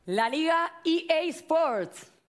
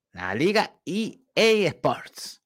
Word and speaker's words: EA Sports: La Liga EA Sports. Ahí está. La Liga EA Sports. Sí, la pronunciación en inglés.